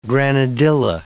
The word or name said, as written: granadilla